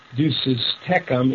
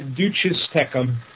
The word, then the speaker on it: duces tecum: Those of you who are former English teachers and sticklers for pronunciation know that the correct pronunciation of a subpoena deuces tecum is just like the playing card, a deuce. And if you have multiple subpoenas, it's deuces tecum. T-E-C-U-M probably would be better spelled T-E-K-U-M. I was trained by a fellow from another part of the country, and he used to pronounce it a subpoena duces tecum, and so in homage to him, I often still do, and I still get a kick out of it.